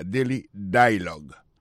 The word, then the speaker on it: Daily Dialogue: Daily Dialogue. Alors, accent tombé sous premier syllabe là.